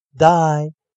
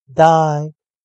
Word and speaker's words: dye: 好了，那同学们，我们今天带来生词，第一个生词 b y e bye bye bye 再见，d y e die die die 给点点点染色，所以同学们要记得 y e。你把它视为 i e，那并且发音一样是长元音时，那这两个生词就可以发音了。最后再跟我老老师一起练习 b y e bye bye bye 再见 d y e die die die 给点点点染色，请同学们跟老师一起多加练习。